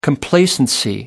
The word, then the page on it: complacency: American English